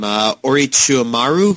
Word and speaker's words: Orochimaru: I'm going to try to say his name, Orochimaru from Naruto.